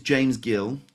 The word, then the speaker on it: James Gill: My name is James Gill, two syllables.